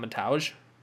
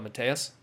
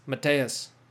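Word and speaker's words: Mateusz: They still call him Mataj. Even though Joe Rogan finally corrected, called him Mateus. You know, because if you just look at his name and just don't pronounce the Z at the end there, you know, it's clearly Mateus.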